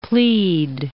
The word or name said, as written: plead